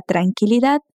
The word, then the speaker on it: tranquilidad: Fíjate que la palabra tranquilidad se pronuncia muy diferente en español. La U de qui no se pronuncia y termina en de. Entonces, no le colocamos ninguna vocal. Tranquilidad.